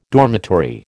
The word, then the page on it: dormitory: American English